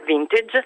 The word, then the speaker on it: vintage: Infatti il significato di questa parola, che io pronuncerei all'inglese, vintage, è appunto vendemmia.